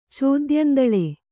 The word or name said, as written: ಚೋದ್ಯಂದಳೆ